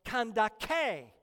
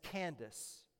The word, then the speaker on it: Kandake: And her title was Kandake. Kandake. It's a name like Pharaoh or Caesar. In verse 27, we can pronounce it as Candace.